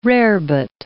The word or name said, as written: rarebit